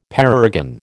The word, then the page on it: parergon: American English